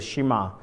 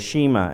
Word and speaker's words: Shema: part of the Shema, or the Shema, as some pronounce it different, but he replies with this, and this is the gospel account.